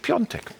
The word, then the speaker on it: Piontek: Aber er spricht sich Piontek.